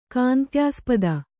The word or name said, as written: ಕಾಂತ್ಯಾಸ್ಪದ